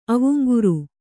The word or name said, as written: ಅವುಂಗುರು